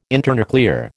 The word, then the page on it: internuclear: American English